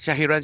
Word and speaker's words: Shahiran: Is it good, Shahiran? – Yes, that's good. That's good. My full name is Shahiran Sharifuddin bin Shahrani Muhammad, but Shah is good.